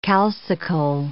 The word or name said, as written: calcicole